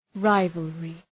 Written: rivalry

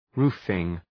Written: roofing

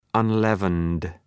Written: unleavened

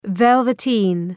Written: velveteen

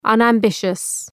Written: unambitious

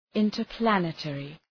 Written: interplanetary

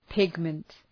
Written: pigment

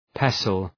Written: pestle

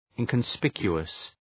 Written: inconspicuous

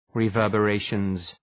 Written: reverberations

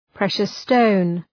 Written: precious stone

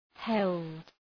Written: held

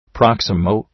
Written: proximo

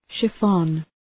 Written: chiffon

